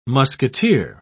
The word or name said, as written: musketeer